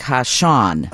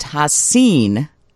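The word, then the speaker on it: Ha'Sean: It's spelled HASEAN, so you would think Hashan. – Oh. – But it's actually pronounced Hasin, phonetically, yeah. – Hasan. – Yeah. – But people outside the family often pronounce it Hashan.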